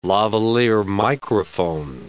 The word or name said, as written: lavaliere microphone